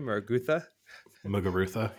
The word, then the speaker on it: Muguruza: Muguruza, yeah.